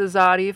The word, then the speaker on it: Xsati: It's spelled X-S-A-T-I. And it's pronounced with a Z. Zati."